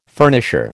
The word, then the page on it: furnisher: American English